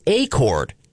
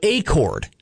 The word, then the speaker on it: Acord: It's Acord, like acorn, but Acord. Not like the model of a Honda sedan, which is an Accord, or an agreement that you come with somebody.